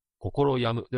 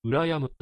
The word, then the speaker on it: 羨む: 羨む。心に病むと書いて、心病むではなく、羨むと読みます。